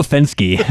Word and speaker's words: Fenske: Every yeah, time you say his name, I always think you're going to say something inflammatory because it sounds like you're saying no offense. No offense. right? Uh, no Fenske.